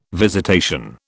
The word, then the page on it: visitation: British English